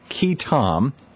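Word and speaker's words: qui tam: John has won a number of, I believe it's pronounced Key Tom. Is that right, John? – Yeah, that's correct. – Key Tom lawsuits brought by whistleblowers,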